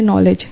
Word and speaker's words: knowledge: And as he said, they will share knowledge. K is silent, but it's knowledgeable.